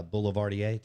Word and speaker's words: Boulevardier: And now the uh, Boulevardier.